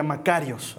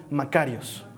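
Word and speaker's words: Macarios (μακάριος): Esta palabra bendecido dedo una palabra que se pronuncia Macarios. A ver, di conmigo, Macarios.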